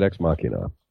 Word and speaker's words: Ex Machina: shifting gears, I want to talk about my favorite sci-fi movie of the last 6 months, which is I pronounce it Ex Machina.